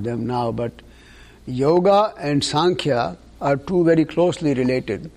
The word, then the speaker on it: Sankhya: Anybody here heard the term Sankhya philosophy? – Not sure. Can you spell it? S-A-N-K-H-Y-A. Sankhya philosophy.